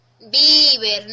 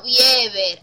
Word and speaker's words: Bieber: Y por cierto, se pronuncia Bieber, no Bieber, analfabeto de mierda.